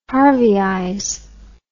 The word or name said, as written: harveyize